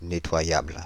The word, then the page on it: nettoyable: Paris; [ne.twa.jabl]